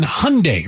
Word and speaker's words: Hyundai: Saying, and I didn't know this, apparently Kia and Hyundai, rhymes with Sunday.